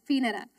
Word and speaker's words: Peninnah: I had to look up to make sure I was pronouncing it right. It's Peninnah, his wife, and to all her sons and daughters.